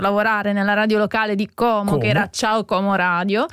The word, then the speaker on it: Como: che noi, tutti quanti, i miei conterranei dicono Como, ma la pronuncia in italiano è Como, anche Como. Perché pure in dialetto è Com, con la O aperta,